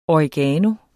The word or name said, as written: oregano